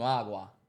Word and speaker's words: agua: you need some agua. – I'll be trying to tell the nigga without cutting him off. Leave the joint up here. Did I say it right? Agua? – Yeah.